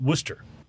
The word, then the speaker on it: Worcester: Very influential and popular town called, well, the way it's spelled is Worcester, but you pronounce it Worcester.